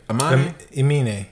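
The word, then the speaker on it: Aminé: Amine? Is that how you say it? – Amine.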